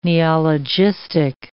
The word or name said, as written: neologistic